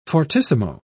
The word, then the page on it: fortissimo: British English